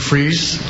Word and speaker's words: Fries: My name is Jason Fries. Last name is spelled F-R-I-E-S.